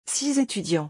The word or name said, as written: 6 etudiants